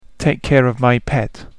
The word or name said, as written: take care of my pet